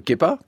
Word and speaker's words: Kepa: Kepa comme Kepa, comment ça se prononce en anglais? – Kepa, mais c'est pas anglais, c'est basque, c'est le surnom de ma grand-mère, ça se prononce Kepa, mais en anglais ça se prononce Kepa, mais j'avais pas trop pensé à ça.